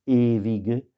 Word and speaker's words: ewige: Das ewige, ewige, c'est le W, se prononce là aussi, bien sûr, comme dans Die Welt, ça se prononce comme un V.